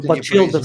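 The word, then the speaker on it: Batchelder: я со своей стороны очень рекомендую посмотреть э, keynote э, Неда с э, непроизносимой фамилией, как его, Батчлер, Батчелдер. – Батчелдер, скорее всего. Батчелдер.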